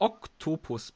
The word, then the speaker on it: Oktopus: Mehrzahl von Oktopus, Moment, laut Duden, äh, Oktopus der, Aussprache Oktopus, Betonung auf dem O.